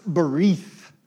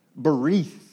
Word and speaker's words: berith: And in the original language, for sort of the two of you that care, the Hebrew word looks like this, covenant, and it's pronounced berith, berith. And very simply, the word covenant means to cut.